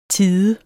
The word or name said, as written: tide